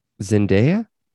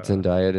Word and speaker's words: Zendaya: But I would watch Donald Glover and and Zendaya, or Zendaya. Did I say it wrong? Not Zendaya. Zendaya.